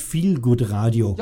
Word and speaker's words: Feelgood Radio: Ja, das steht so da, ja, das wird aber Englisch ausgesprochen, das heißt Feelgood Radio.